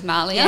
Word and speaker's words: Malia: I pronounce it as, I say it as Malia.